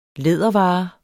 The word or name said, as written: lædervare